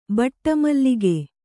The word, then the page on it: ಬಟ್ಟಮಲ್ಲಿಗೆ: baṭṭa mallige